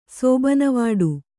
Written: ಸೋಬನವಾಡು

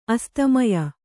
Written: ಅಸ್ತಮಯ

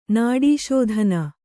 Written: ನಾಡೀಶೋಧನ

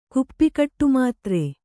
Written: ಕುಪ್ಪಿಕಟ್ಟುಮಾತ್ರೆ

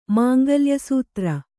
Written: ಮಾಂಗಲ್ಯಸೂತ್ರ

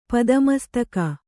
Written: ಪದಮಸ್ತಕ

